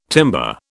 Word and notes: timber: British English

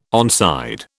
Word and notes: onside: British English

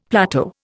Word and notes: plateau: Indian

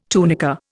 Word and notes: tunica: Indian

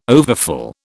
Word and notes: overfull: British English

